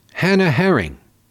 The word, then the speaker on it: Herring: Received a statement from Publix supermarket spokesman Hannah Herring, which rhymes with Hermann Goering.